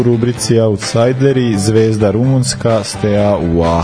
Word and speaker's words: Steaua: I onda, sam ja ispravio ljude da je Steaua. – to je najgori mene. – Tako da sam tri put googlao danas kako se izgovara. Znači, našao sam tri YouTube videa da vidim kako se izgovara Steaua. Jeste Steaua, jeste zvezda.